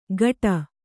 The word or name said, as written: ಗಟ